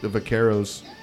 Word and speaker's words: vaqueros: Yeah, we went back and we went back to, you know, the vaqueros, and I'm not saying it correctly, right? – It's still cute. – Yeah, right. – Your R's are wrong. – I gotta roll my R.